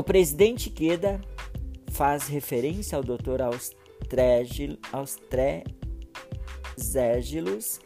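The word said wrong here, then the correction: Austregésilo: de Ataíde, um nome difícil de pronunciar, mas é, é isso aí, Austrigésilo de Ataíde, né?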